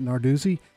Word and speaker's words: Narduzzi: After leading Pittsburgh to its first ACC championship this year, Pat Narduzzi. I know I said that wrong. No, you're fine. Uh, go with that. I think that's fine. Okay.